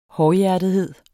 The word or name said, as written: hårdhjertethed